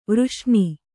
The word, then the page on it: ವರ್ಷಣ: kannada